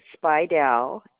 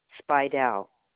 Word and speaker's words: Speidel: Yes, my name is Beverly Speidel, Speidel. – And Beverly is spelled Beverly? – Beverly. – And last name is spelled Speerdel, correct? – No, Speidel. Speidel. Can you look me up by phone number? – Oh, let me see here.